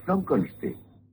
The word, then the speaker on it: Frankenstein: Frankenstein. ¿Me toma el pelo? No, se pronuncia Frankenstein.